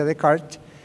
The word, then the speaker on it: Descartes: Ahora, un tal René Descartes, aquí se escribe Descartes, se pronuncia Descartes,